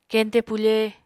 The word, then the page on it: keñte pufe: Apatani